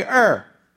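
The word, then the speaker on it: Er: Hey, Ur. Come here, Ur. It's spelled E-R.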